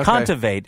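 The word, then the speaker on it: Kontaveit: Was that the pronunciation? – Contivate. – Okay. Contivate is how it's pronounced.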